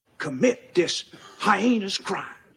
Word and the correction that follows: heinous: That's the proper pronunciation of the word is heinous.